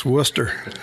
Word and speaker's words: Wooster: It's Worcester.